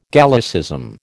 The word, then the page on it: Gallicism: American English